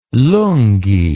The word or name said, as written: lungee